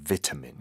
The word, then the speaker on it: vitamin: You see the British pronunciation close to the Korean one, vitamin.